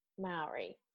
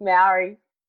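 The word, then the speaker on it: Māori: Maori. – Ri. – Maori. – Ri. – Okay, right. I'm just going to continue with Maori. – Roll the R, make it sound like a D. – Maori.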